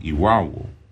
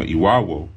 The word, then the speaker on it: Irawo: But you hear that just in the name, Iwawo, Iwawo. What do you hear there? Awo. It's, you know, Iwawo, the word for star is spelled I-R-A-W-O. A-W-O, that's the key.